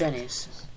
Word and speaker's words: Enies Lobby: No, it's Enies.